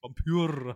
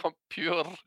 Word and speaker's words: Vampyr: Ich bin mir sicher, dass es Vampyr heißt. – Vampyr. Alles klar, so wird das ausgesprochen. Ja, es heißt Vampyr.